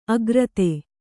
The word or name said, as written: ಆಕೃತಿ